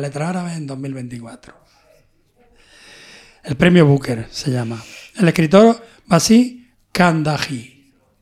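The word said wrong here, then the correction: Bassem Khandakji: Repite el nombre, por favor. – Bassi Kandakji. – Bassem Kandakji.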